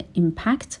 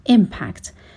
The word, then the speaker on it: impact: کلمه impact اینجا یک فعل هستش، هم میتونیم به صورت impact یعنی استرس روی بخش دوم باشه تلفظ بکنیم و هم میتونیم استرس روی بخش اول بذاریم و بگیم impact. ولی وقتی که این کلمه یک اسم باشه به معنای اثر یا تأثیر، استرس بخش اول کلمه میاد و میشه impact.